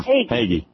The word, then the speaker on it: Hagee: How do I do you to pronounce Hagee. – That? – Hagee. Starve! – Hagee. Oh, okay. – Right. It kind of goes with haggis. Hagee. You know, Hagee.